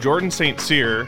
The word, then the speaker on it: Jordan St. Cyr: We're here with Jordan St. Cyr, and if you guys have not heard his name pronounced, that is how it's pronounced, at least I'm assuming.